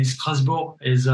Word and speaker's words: Strasbourg: I mean, Strasbourg is, uh, is, is pretty much the French way of saying it.